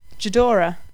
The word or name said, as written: jhudora